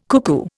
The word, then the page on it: cuckoo: Indian